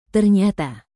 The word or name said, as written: ternyata